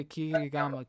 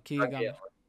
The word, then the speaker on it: Kageyama: Kiyagama. – Kageyama. – Kagiyama? – Yeah, Kageyama.